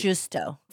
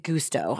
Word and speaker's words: gusto: Thinks that gusto is pronounced, how do you say it? – Justo. – Justo. He was approaching these cons and serial killings with gusto, I would say.